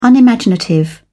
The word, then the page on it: unimaginative: British English